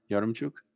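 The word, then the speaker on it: Yaremchuk: Yaromchuk, is that how you pronounce it? Yaromchuk? – Yes.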